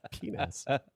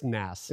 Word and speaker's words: PNAS: Penis. – For something that affects, the P is silent. It's just NAS. – It's just NAS. It's like pneumatic.